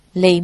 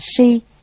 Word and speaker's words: si: Lị mị võng lượng. Lị mị, lị đúng ra phải đọc là si, là loài quỷ trong núi sâu.